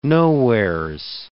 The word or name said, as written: nowheres